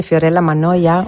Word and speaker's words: Fiorella Mannoia: Sí, este tema se llama Mariposa y es de Fiorella Mannoia, una gran intérprete y cantante italiana.